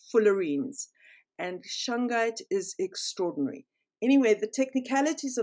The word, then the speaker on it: shungite: and shungite is spelled S-H-U-N-G-I-T-E, shungite, it comes from, I think, Siberian area, somewhere in Russia.